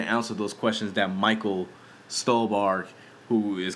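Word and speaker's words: Stuhlbarg: And I think one thing he does well is with the two characters of Peter Sarsgaard and Michael Stolbe. How the fuck you pronounce this dude's name? Oh, Stolbark, yeah.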